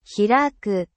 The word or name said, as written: hiraku